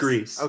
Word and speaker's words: Gris: It is pronounced Greece. It's Spanish. – I know it's Spanish, but I'm pretty sure the devs... – No, they said Greece.